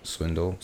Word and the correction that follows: Swindell: I'm pretty sure it's pronounced Swindell.